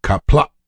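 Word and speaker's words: Qapla': That's where I learned that the apostrophe is a guttural stop. So when you say success in Klingon, kapla, that the end of the word has the apostrophe there, and you have a guttural stop at the end of that word.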